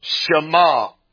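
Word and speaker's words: Shammah: According to Hebrew, that's what you would accent the second syllable, Shema.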